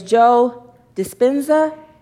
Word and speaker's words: Joe Dispenza: His name is Joe Dispenza.